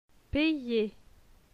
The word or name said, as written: payer